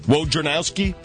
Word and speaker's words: Wojnarowski: How do you say it? Wojnarowski. – The N is right after the J. – Wojnarowski.